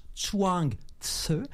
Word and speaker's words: Chuang Tzu: On prononce Chuang Tzu. Le Tzu, c'est une sorte de bourdonnement.